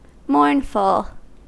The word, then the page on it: mournful: American English